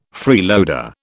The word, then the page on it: freeloader: British English